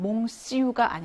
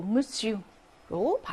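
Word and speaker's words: monsieur: 5번은 무슈라고 해서 이건 ON 할때 항상 발음으로 문제로 많이 나오는 건데 이거는 몽슈가 아니고 무슈로 발음이 된다라는 거죠.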